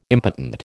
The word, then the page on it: impotent: American English